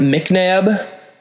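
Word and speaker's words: MacNabb: It's pronounced McNabb, but it's spelt M-A-C. So if you're trying to find me on Twitter or something, don't search M-C-N-A-B. It's M-A-C-N-A-B.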